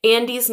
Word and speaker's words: Ande: Andy's name is spelled A-N-D-E because, well, I don't know why, but it's pronounced Andy.